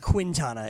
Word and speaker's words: Quintana: I said, yes, you pronounced the U in Cueto, but not in Quintana, just so you know. – Oh, so it's not Quintana, it's Quintana. – Quintana.